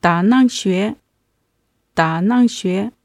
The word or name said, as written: dannangxue